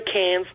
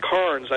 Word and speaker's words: Cairns: Now, you say Cairns, like it's pronounced Corns over here, but we pronounce it Cairns down there, like beer cans.